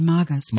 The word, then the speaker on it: Magus: It should actually be Magus. – Magus? Okay. Mm-hmm. That's what I thought, but then I slipped back into old habits. – Well, if there were an I after the G, it would be J, mm-hmm. but it's a hard G if it's just a U after it.